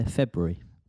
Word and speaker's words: February: Yeah, February. – People say it differently, don't they? It's got an R in there.